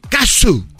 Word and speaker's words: Cazzu: Casu, Kazu. ¿Ah, se escribe C-A-Z-Z-U. Kazu.